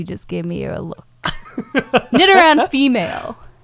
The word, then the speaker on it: Nidoran: I was saying it wrong earlier. So it's Nidoran? – Nidoran, yeah.